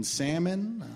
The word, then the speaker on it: salmon: Like, I should have known that we weren't going to be compatible the second I heard her pronounce the L in salmon.